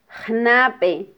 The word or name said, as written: jnabe